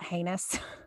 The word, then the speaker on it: heinous: I told you I had something to tell you about heinous, the word heinous. – Yeah. Oh yeah. Heinous, that rhymes with anus.